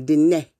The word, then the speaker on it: Diné: And so you say it looking up to the sky, Diné, which will give you the proper pronunciation of that tribe.